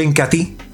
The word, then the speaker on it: Incatea: Se escribe Incatea, pero se pronuncia Incati.